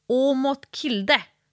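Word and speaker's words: Aamodt Kilde: Och sen fick jag tips av samma tjej, som handlar om den norska störtloppsåkaren Åmot Kilde.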